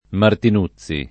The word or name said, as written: Martinuzzi